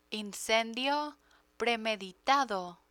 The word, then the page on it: incendio premeditado: Spanish